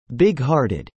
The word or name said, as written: bighearted